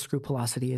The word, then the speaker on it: scrupulosity: If I had kept that a secret, idealist scrupulosity, is that how you pronounce it? – Yeah.